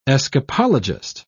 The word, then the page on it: escapologist: British English